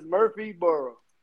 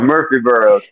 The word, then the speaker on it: Murfreesboro: I can't say it correctly, probably Murfreesboro. There we go. – Like Eddie Murphy is Murfreesboro. – Oh, there we go, Murfreesboro.